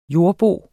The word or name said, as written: jordbo